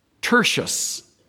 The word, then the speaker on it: Tertius: Chapter 16, verse 22 is a man whose name is pronounced Tertius.